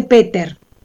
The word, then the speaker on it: Peter: Una de Peter Hanke, que no se dice Peter, o cosa rara, no se dice Peter porque resulta que es alemán, entonces se dice Peter.